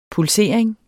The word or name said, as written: pulsering